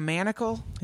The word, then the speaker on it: manacle: If you're talking about games or anything, it's a manticle of sorts. – You mean a manacle? – Yeah, a manacle. – I thought you said manticle. – I think I did. I think I said it wrong.